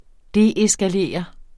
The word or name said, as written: deeskalere